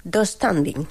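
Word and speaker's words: döstädning: Yo lo pronuncio... Dos standing. – Sí, así. Sí, tengo esa silla de IKEA, sí, se pronuncia así.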